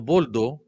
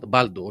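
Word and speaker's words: Baldo: Α, για τον Μπόλντο που λέει ο. Μπάλντο, όχι Μπόλντο.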